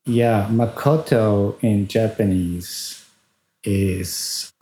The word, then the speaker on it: Makoto: Makoto.